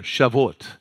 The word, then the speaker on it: Shavuot: The Pentecost, the 50 days after the previous feast day, pronounce Shavuot. Shavuot. Say that with me. I'm not even saying it right. Shavuot. Now jam them together. Shavuot.